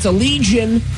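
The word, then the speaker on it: Allegaeon: It's Allegion. We finally found out how to say their name this year.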